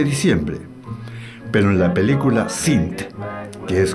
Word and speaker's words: Sint: La última película a comentar de esta lista de varias, Sint. Así se escribe, S-I-N-T, Sint, del año 2010.